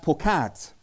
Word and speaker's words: paqad: It's pronounced pokad, P-A-Q-A-D.